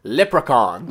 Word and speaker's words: leprechaun: Leprechaun. – Voilà. Il va se faire passer pour un... – Donc un leprechaun, c'est un genre un farfadet. – Ouais, voilà. – Leprechaun, il y en a qui disent en français.